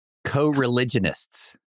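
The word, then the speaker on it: coreligionists: be an informant against some of his co-religiousists. How do you say that? – Co-religionists. – Religionists is the NF. – Religionists, yes.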